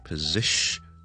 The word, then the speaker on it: position: 咁咧就系 position position position position position。而第一个音节咧，我哋唔系读 po 嘅，系 p p p。跟住后面 second syllable stress it position position position，跟住 tion 就得啦，position position, position, position，咁咧就会读好呢个字啦。